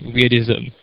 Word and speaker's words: wyrdysm: It's pronounced like weirdism.